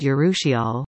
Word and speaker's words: urushiol: It's not your imagination, it is the allergy-inducing sap called urushiol, pronounced like this, and it's stronger than it was before carbon dioxide levels started to climb significantly in recent years.